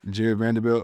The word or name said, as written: Jarred Vanderbilt